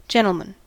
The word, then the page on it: gentlemen: American English; [ˈdʒɛn.tl̩.mən]